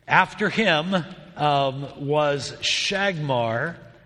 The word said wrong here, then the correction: Shamgar: I'm saying that wrong. It's written wrong. It is Shamgar.